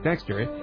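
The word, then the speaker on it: Dextre: With 11-foot arms, a soldier's shoulder span of nearly 8 feet and a height of 12 feet, the Canadian Space Agency's Dexter, short for Dexterius and pronounced like Dexter, is more or less intimidating, at least for the astronauts.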